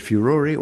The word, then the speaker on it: furore: Now, this is F-U-R-O-R-E. It comes from a Latin word, furor, F-U-R-O-R, meaning rage or anger. And what's happened is that the Americans leave the final E off, and so they normally pronounce it furor. But in our English, we keep the E on the end, and the dictionaries prefer the pronunciation furore. They also allow furor for people in Australia, so you can have it either way.